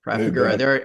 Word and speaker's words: Trafigura: And, you know, you had that report early last month about Trafigura. Did I pronounce that one right? – Trafigura.